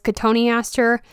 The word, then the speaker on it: cotoneaster: Another foliage based plant is cotoneaster. It's spelled like cotton Easter.